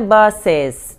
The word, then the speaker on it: buses: E o S é, não é buses, não. O, o E quase que não pronuncia, é como se fosse um S atropeladinho, assim, sabe?